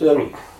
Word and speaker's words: Eric: And yet he'll know it's Eric. How would you spell it? – E-R-I-C.